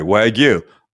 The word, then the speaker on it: Wagyu: Listen, he said it one way, and I sent Greg 4 or 5 examples of people pronouncing it the proper way, Wagyu, from the internet.